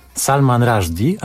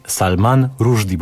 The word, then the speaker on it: Salman Rushdie: To jest, proszę państwa, fragment serialu Pohamuj swój entuzjazm, w którym gościnnie wystąpił Salman Rushdie, a raczej Salman Rushdie, bo tak powinno się wymawiać nazwisko tego pisarza.